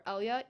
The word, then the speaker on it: Elya: That's just Emerson, just one M underscore Elya, ELYA. It's two syllables, not three. Elya. Just to let you know. – Why is that? Is that something? – Most people think it's three syllables.